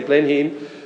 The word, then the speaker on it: Blenheim: Blenheim, is that how you pronounce it? Yeah, Blenheim Palace.